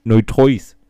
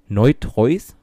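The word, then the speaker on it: neutrois: Auch hier, wie mit den, mit den Geschlechtern, ich wusste noch nicht mal, es gibt so viele Geschlechter hier, ne Neutreus. Äh, wie, hä? Wie würdest du das aussprechen? Ich buchstabiere dir mal. Das wird geschrieben N-E-U, wie neu, und dann T-R-O-I-S. Neutreus? Neutreus.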